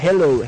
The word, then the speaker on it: Halo: Entonces, ahí en el Facebook, luego, están, los enlaces, y por ahí, bueno, me están diciendo que Halo se pronuncia Hello, Halo.